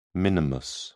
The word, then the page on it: minimus: British English